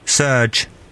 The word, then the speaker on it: Serge: Out of Kasabian, is it Serge or Sergey? – Serge.